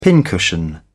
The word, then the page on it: pincushion: British English